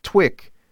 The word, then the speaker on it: TIWK: TIWK, pronounced TWIC, stands for Two Incomes with Kids.